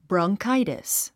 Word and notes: bronchitis: brɑŋkáitis; ブロンカイデス

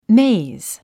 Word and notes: maze: méiz; メェイズ

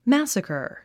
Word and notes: massacre: mǽsəkə r; マァッサカァ

